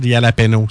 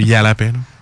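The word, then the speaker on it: jalapeño: Écoute, les manchettes Jalapeno, c'est pas compliqué, les manchettes Jalapeno. C'est correct, ma prononciation? Là? – Non, mais c'est pas grave. – C'est Jalapeno.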